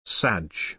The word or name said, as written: sadge